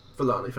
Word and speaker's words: Forlani: The aforementioned girlfriend of Quint, played by Claire Forlena. I'm not sure how you pronounce it. – Forlani. – Forlani, thank you very much.